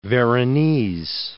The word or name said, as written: Veronese